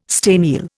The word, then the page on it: staniel: Indian